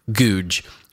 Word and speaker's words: Gouge: Now, his name is spelled G-O-U-G-E, but it's pronounced Googe.